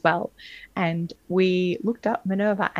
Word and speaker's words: Minerva: I hope I'm pronouncing that right. Maverna. – Minerva. We were calling it the wrong thing for a while. We have to confess. – Minerva. – We're also very Australian, so we've never heard a British person say it as every day on their back. – It's spelt Minerva, and it might be our Australian accents, but we interpret that as Minerva. – But I think we might be a bit heavy on the middle vowel.